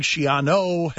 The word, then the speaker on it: Schiano: I think earlier reports is inaccurate, as was my pronunciation initially of Schiano. I was pronouncing it Schianu, but in our zeal to be first instead of right, we reported inaccurately that Greg Schiano had been hired by ESPN, and we subsequently celebrated that hire with cheering for some reason.